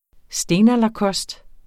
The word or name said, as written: stenalderkost